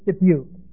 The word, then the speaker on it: Dubuque: Dubuque? How do you pronounce that? Dubuque.